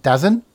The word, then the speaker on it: DAZN: And finally, I've forgotten how to pronounce it now, D-A-Z-N, Dazn? – DAZN.